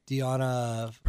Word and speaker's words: Deonna: Is that how you pronounce that? – Yeah, Deonna Purrazzo.